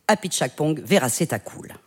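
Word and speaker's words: Apichatpong Weerasethakul: ce serait Cemetery of Splendor du Thaïlandais Apichatpong Verasethakul.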